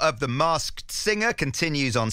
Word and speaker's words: Masked Singer: It's because I'll be honest, every single time I say Masked Singer on the show, I just completely forget the K because it's quite difficult to pronounce.